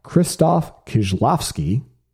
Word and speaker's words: Krzysztof Kieślowski: And I started thinking of some of my favorite movies from Krzysztof Kieślowski, which is a name I would not have been able to pronounce without that parenthetical.